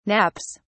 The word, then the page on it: naps: British English